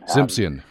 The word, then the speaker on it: Simsion: Graeme, like Graham Crackers and Simsion. – Simsion, okay.